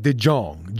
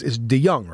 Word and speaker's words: DeJong: Is that how you pronounce that? It looks like DeJong, J-O-N-G, but it's not. And it's DeJong, right? Chase DeJong for the Bucks, Caleb Smith for the Diamondbacks.